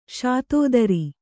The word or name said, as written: ಶತಧಾರ